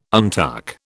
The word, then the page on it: untuck: British English